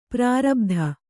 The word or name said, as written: ಪ್ರಾರಬುದ